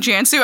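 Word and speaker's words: Cansu: And I will have a disclaimer for the folks here that are looking at the podcast title spelling your name with a C, and I'm saying Jansu. I'm sure you get this all the time. I am pronouncing it correctly. The C sounds like a J.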